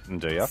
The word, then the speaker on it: Nduja: And it's spelt N-D-U-J-A. You pronounce it as Nduja, but everyone calls it Nduja.